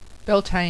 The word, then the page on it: Beltane: Bell-tayn